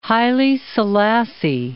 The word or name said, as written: Haile Selassie